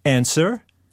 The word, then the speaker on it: answer: Or answer. – P. W. – W, yeah.